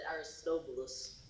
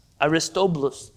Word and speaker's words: Aristobulus: Aristobulus, maybe. He is the younger brother of Hyrcanus. – I think you pronounce it Aristobulus. – Aristobulus. Very good.